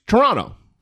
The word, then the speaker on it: Toronto: But they don't pronounce that second T. It's just Toronto.